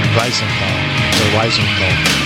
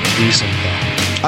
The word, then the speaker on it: Wiesenthal: So how did I mispronounce the Wiesenthal? – You said Wiesenthal. – And what is it? – Properly pronounced Wiesenthal.